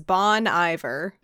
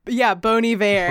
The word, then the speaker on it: Bon Iver: There's a band, and their name spelled out, if you don't know how it's pronounced, says Bon Iver. – Oh, yeah. – Bon Iver. – But it's pronounced, Bon Iver.